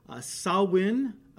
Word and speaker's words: Samhain: And Halloween, October 31st, is the fall climax, known as Samhain or Samhain. Sometimes it's pronounced. It's spelled S-A-M-H-A-I-N. Samhain, I think is mostly how it's pronounced.